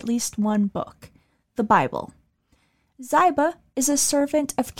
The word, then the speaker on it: Ziba: In some older videos and interviews, you might hear staff members, mostly myself, refer to Ziba as Ziba, but the family corrected us as to the correct pronunciation of the name.